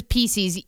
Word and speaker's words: Pieces: I know people have a problem with how you say Reese's Pieces. – Pieces. – Sorry. – There you go. You got it the second time.